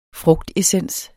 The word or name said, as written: frugtessens